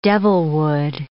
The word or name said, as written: devilwood